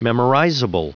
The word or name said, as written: memorizable